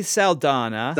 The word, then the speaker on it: Saldana: Zanata. I just said her name wrong. Wow. – Zoe Saldana. – Saldana, thank you.